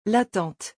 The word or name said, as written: La tante